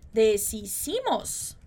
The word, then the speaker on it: deshicimos: Apesar de a gente ter esse SH ali em cima, você vai ver que a gente não vai falar deshicimos, né? Como se fosse aquele som de sh, de chiado, né? De SH que a gente pronunciaria no português. Então, muito cuidado com essa pronúncia.